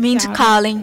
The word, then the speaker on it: Mindy Kaling: Mindy Kaling.